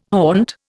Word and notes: haunt: Indian

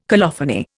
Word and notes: colophony: Indian

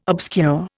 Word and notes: obscure: Indian

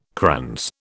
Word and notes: krans: British English

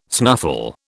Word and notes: snuffle: British English

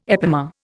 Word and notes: epimer: Indian

